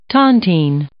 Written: tontine

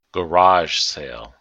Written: garage sale

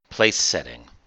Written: place setting

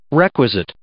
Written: requisite

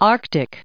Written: Arctic

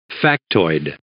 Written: factoid

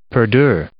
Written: perdure

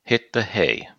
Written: hit the hay

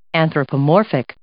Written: anthropomorphic

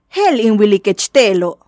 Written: Je'el in wilikech te'elo'

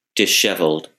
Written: disheveled

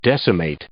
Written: decimate

